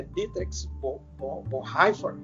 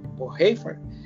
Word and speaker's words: Dietrich Bonhoeffer: E esse alemão aí que foi executado na Segunda Guerra Mundial, acho que a pronúncia é Dietrich Bonhoeffer, Bonhoeffer teólogo alemão, ele diz que é comprometimento com Cristo.